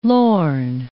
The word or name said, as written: lorn